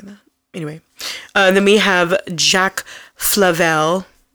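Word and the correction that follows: Favell: Did I say that right? – Favel.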